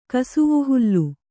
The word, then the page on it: ಕಸುವುಹುಲ್ಲು: kasuvuhullu